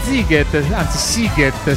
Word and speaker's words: Sziget: E tra pochissimo parliamo di Sziget, si pronuncia così,